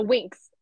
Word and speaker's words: Winx: she's the one from the Windex I think that's how you say it. The Windex series on Netflix. The Winx.